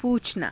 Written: puuchanaa

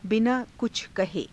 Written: binaa kucha kahe

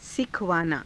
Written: sikhavaanaa

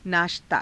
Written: naashtaa

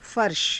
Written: farsha

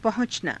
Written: pahu~canaa

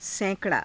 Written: saika_raa

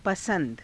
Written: pasa^da

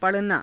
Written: pa_ranaa

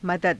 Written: madada

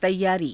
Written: taiyaarii

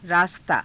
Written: raastaa

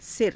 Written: sira

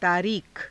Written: taariikha

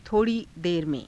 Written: tho_rii dera me^

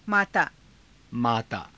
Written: maataa

